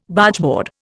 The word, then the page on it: Bargeboard: Indian